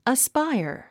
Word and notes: aspire: əspáiə r; アスパイア

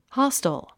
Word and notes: hostel: hάstl; ハステル

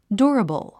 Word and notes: durable: djúərəbl; ドゥラボゥ

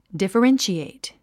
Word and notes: differentiate: dìfərénʃièit; ディファレンシエイト

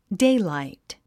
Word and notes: daylight: déilàit; デイライト

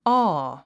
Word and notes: awe: ɔ́ː; オー

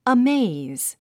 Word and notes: amaze: əméiz; アメェイズ

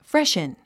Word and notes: freshen: fréʃən; フレシュン

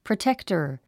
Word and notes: protector: prətéktə r; プロテクタァ

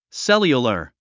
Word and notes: cellular: séljulə r; セリュラァ

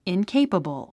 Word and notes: incapable: inkéipəbl; インケイパボォ

